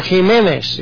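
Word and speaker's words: Jiménez: The 20th link, the Complutensian Polyglot of 1522, by a Roman Catholic cardinal, Cardinal Jimenez, or Jimenez, if you want to have the Spanish pronunciation, used the Textus Receptus.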